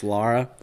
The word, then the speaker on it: Lara: I think Lara Croft would actually be the better. It's actually pronounced Lara.